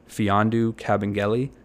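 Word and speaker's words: Mfiondu Kabengele: Now, after Harrell, who would technically be the backup center on the Clippers, the next center on their roster is rookie Fiondu Cabangeli. And yes, I did say that correctly. You could look it up, I swear.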